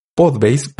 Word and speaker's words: Podbase: Este sitio es Podbase, así se pronuncia, pero se escribe POD ba.se.